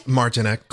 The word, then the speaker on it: Martineck: Martineck.